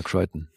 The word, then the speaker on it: Crichton: Ja, jetzt weiß ich natürlich auch anhand des Making-ofs und Co., dass er Crichton ausgesprochen wird. Neulich waren wir uns nicht sicher, ob das Crichton oder Crichton heißt. – Ja, habe ich auch recherchiert. Ja, Crichton. – Crichton heißt der Mann.